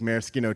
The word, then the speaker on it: maraschino: So I remember he would fight with people about how to pronounce maraschino, like maraschino cherries, which it's a hard C, but people say maraschino, which is incorrect.